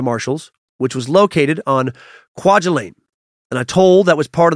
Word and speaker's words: Kwajalein: By January 31st, Kwajalein, that's a tough one, was devastated. It doesn't look like it should be pronounced that way.